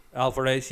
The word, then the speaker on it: Alvarez: I know the director, Fede Alves. Hope I'm pronouncing his name correctly. – Alvarez, has stated that it's set in the same universe as the original.